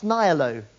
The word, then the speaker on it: nihilo: The technical term, in case you come across it, for this is creation ex nihilo, N-I-H-I-L-O. From nothing. Have I pronounced that correctly? Nihilo. Okay, right. I didn't do Latin.